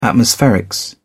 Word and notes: atmospherics: British English

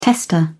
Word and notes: tester: British English

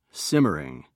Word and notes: simmering: British English

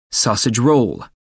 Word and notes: sausage roll: American English

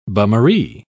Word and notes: bummaree: British English

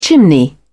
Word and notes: chimney: British English

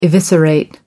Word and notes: eviscerate: American English

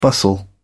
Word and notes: bustle: British English

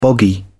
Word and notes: boggy: British English

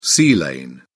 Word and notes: sea lane: British English

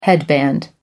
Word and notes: headband: American English

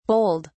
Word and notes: bowled: British English

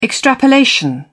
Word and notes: extrapolation: British English

